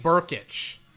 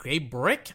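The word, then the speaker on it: Brkić: Gabe Burkich. It's spelled B R K I C. I I I wouldn't I was like Gabe Brick? I thought the K was silent.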